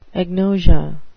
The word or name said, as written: agnosia